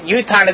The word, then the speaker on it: yutan: As it's read yutan as if it had a vav, but it's spelled yitain without a vav.